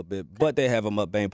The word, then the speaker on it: mukbang: It's a mukbang, is that pronouncing it right? – Yeah.